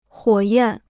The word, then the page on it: 火焰: huǒ yàn